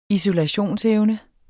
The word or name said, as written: isolationsevne